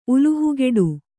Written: ಉಲುಹುಗೆಡು

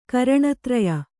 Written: ಕರಣತ್ರಯ

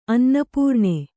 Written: ಅನ್ನಪೂರ್ಣೆ

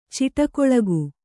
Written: ಚಿಟಕೊಳಗು

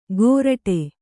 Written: ಗೋರಟೆ

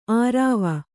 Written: ಆರಾವ

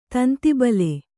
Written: ತಂತಿಬಲೆ

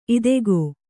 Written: ಇದಕೋ

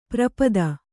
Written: ಪರಿಪೀತ

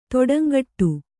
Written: ತಡಂಗೂಡು

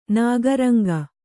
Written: ನಾಗರಂಗ

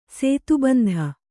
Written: ಸೇತುಬಂಧ

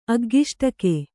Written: ಅಗ್ಗಿಷ್ಟಕೆ

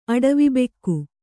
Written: ಅಡವಿಬೆಕ್ಕು

